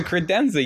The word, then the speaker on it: credenza: Are you saying it right? – Oh, I surely am. It's a credenza. You put your thing on it. – Is there an R? Is there an R in...